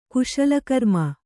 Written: ಕುಶಲಕರ್ಮಿ

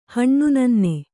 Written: ಹಣ್ಣುನನ್ನೆ

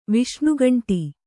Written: ವಿಷ್ಣುಗಂಟಿ

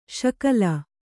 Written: ಶಕಲಿ

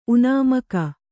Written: ಉನಾಮಕ

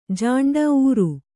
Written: ಝಿಂಟಿ

